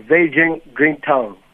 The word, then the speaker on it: Zhejiang Greentown: I'm saying, was I pronouncing that correctly? – Yeah, Zhejiang Greentown.